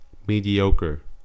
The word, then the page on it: mediocre: American English